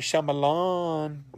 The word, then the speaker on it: Shyamalan: How do you say his name? M. Night Shyamalama, Shyamalama Shyamalama Dean, Shyamalama Dean, M. Night Shyamalan.